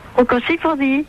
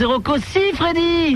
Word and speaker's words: Rocco Siffredi: Rocco Siffredi. – Eh non, c'est Rocco Siffredi. – Freddy, oh ben j'ai mal prononcé.